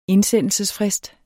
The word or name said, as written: indsendelsesfrist